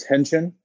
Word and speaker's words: Haenchen: My last name is Haenchen. The A is silent.